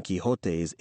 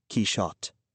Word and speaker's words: Quichotte: Portuguese also uses a sh sound rather than a h sound for the X or J in the middle of Don Quixote's illustrious name. Cervantes himself would probably have said Quixote in the Spanish of his time. For the purposes of this text, the recommended pronunciation is the elegant French Quichotte, for reasons which the text itself will make clear.